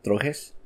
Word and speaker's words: Trojes: And how do you say T-R-O-J-E-S? Trojes? – Trojes. – Okay. I didn't want to butcher that.